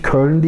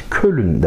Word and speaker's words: Köln: Yani bizim e, milletimiz Köln diyemez. Kölün der. Bunu hep örnek veriyorum ya.